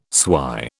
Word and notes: swy: British English

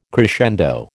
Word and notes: crescendo: American English

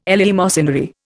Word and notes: eleemosynary: Indian

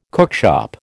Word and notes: cookshop: American English

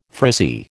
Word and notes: frizzy: American English

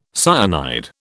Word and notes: cyanide: British English

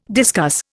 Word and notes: discuss: Indian

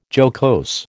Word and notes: jocose: American English

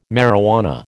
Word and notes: marijuana: American English

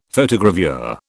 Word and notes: Photogravure: British English

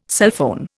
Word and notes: sulphone: Indian

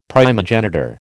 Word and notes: primogenitor: American English

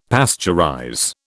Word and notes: pasteurize: British English

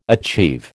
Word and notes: achieve: American English

